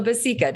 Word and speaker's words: Bacica: This is Carla Basica.